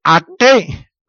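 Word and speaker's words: Aké: Por ejemplo, también está el apellido Aque, que se escribe A-K-E, Aque con acento en la E.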